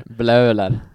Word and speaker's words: Bleuler: Bleuler. – Eugene Bleuler. – Bleuler.